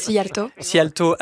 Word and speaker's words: Szijjártó: Szijjártó